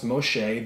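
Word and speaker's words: Moshe (משה): And one of the things that I discover is they believe, through extra-biblical texts and history, that Moses, his name would have been pronounced Moshe,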